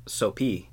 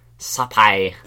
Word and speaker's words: Sophy: To the untrained eye, it's Sophie. S-O-P-H-Y is how you spell it. But she's Cambodian, and in Cambodia, they don't have a soft P-H. – Oh. – So in reality, her name is pronounced Sophie.